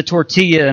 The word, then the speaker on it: tortilla: And to take away all of the good stuff, that would be the carbs and the tortilla and all of that. I got some Spanish speaking folks. Did I say that right? Tortilla? Yes? All right.